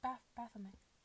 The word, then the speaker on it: Baphomet: Baphomet.